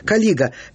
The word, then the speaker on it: калига: А Калига это у Калига, простите, оговорился, у римлян не надо говорить на первый слог ударение. Калига это обувь легионера.